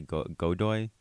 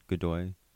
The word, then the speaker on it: Godoy: I'm going to totally butcher this last name, but it's spelled G-O-D-O-Y Godoy. Godoy. Lizbeth Godoy. – Shout out to like good day.